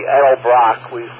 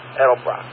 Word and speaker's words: Edelbrock: I know it's the letter E, but I think it's almost pronounced like an A. – No, with an E. Edelbrock. That's the way we pronounce it. Edelbrock.